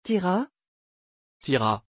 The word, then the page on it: tira: French